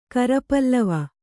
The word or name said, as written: ಕರಪಲ್ಲವ